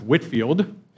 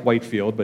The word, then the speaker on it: Whitefield: Another one of the famous revivalists was George Whitefield. It looks like Whitefield, but he was British, so just don't pronounce some of the vowels.